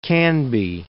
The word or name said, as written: Canby